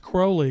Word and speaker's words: Crowley: But his name was pronounced Crowley because it rhymes with holy, I guess.